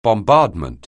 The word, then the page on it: bombardment: anglais